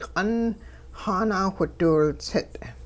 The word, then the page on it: q'ung xaanawhidiwiltsit': Hupa